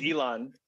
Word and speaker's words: Elon: Is that how you say his name, Elon? – I think it's Elon. Elon Musk. But it is how you say his name. Yes.